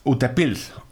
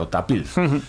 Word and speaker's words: Utepils: Es Utepils. – Utepils. – Es, Utepils. La, como se escribe, Utepils, tal cual, con S al final, pero se ve que se pronuncia con una Z, Utapils.